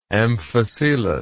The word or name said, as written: amphicelous